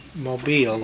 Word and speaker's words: Mobile: Mobile with the accent on the second syllable.